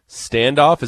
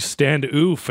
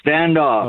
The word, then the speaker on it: Standoff: Is that how you say that? – Yep. – You bet. – It's not like Standoff, and I'm saying it wrong, and people are going to be like correcting me all day. – More like Stand Oof, am I right? – No, it's Standoff.